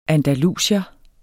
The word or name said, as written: andalusier